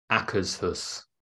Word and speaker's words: Akershus: And it's pronounced Akershus. – Akershus. – Yeah. – Okay. – Yeah. – Akershus. – According to somebody that works there.